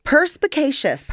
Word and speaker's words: perspicacious: Perspicacious. – Perspicacious. – Like you're capricious. – Like Capri Sun.